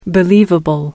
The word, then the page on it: believable: American English